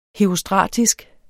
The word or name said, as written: herostratisk